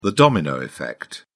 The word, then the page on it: domino effect: British English